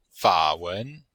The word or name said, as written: fa3wen2